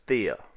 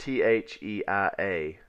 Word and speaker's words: Theia: This planet was known as Theia. It's spelled T H E I A, Theia, and it's from a Greek mythology, uh, Titaness or a female goddess of the moon now.